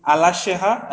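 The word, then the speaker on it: Alaşehir: The city, modern day Alasheha, I don't know how to pronounce that, but it's Alasheher.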